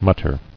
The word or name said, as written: Muttered